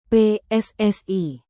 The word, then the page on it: PSSI: Indonesian